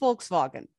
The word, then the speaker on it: Volkswagen: It's actually pronounced Volkswagen. – No. – Yeah, it is. – Not here. – In the proper German, V's are F's and W's are V's.